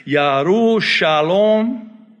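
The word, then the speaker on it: Yerushalayim: Oh, by the way, parenthetically, let me say, Yaru Shalom, Jerusalem, Arabic, Yaru Shalom, they don't pronounce the J in Hebrew.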